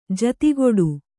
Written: ಜತೆಗೂಡು

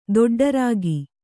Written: ದೊಡ್ಡರಾಗಿ

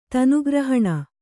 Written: ತನುಗ್ರಹಣ

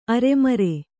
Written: ಅರೆಮರೆ

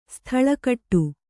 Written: ಸ್ಥಳಕಟ್ಟು

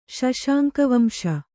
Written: ಶಶಾಂಕವಂಶ

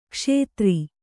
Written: ಕ್ಷುದ್ರ

